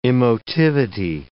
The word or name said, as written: emotivity